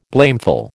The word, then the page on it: blameful: American English